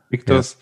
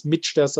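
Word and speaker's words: Michter's: Also beim Mictas, die Aussprache, ich habe auch am Anfang gedacht, man spricht das Mitsters aus.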